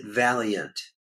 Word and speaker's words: Vaillant: So I'm a big George Vaillant fan, and V-A-I-L-L-A-N-T. How do you pronounce his last name? – He pronounces it Valiant. – I always noticed that, but there's no extra I in there.